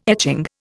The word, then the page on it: etching: Indian